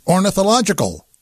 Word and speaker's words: ornithological: I'm John Landecker, and the secret word for today is ornithological. Is that correct, Edward Warden? Did I say it correctly? – I think you nailed it.